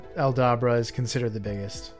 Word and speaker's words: Aldabra: Aldabra. – Aldabra.